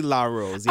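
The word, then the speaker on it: La Rose: which was Natalie Del Rose, which is Somebody with Jeremiah. – Oh, I said her name wrong. Natalie. – Natalie LaRose. Yeah.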